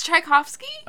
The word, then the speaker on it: Tchaikovsky: It's spelled T C H. It's Tchaikovsky? – Uh huh.